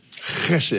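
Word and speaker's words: Hesed (חסד): Now, loving kindness in Hebrew is hesed. Hesed is the way it's pronounced.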